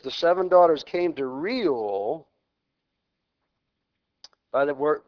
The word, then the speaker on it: Reuel: Jethro and Reuel. I never said that right. Reuel.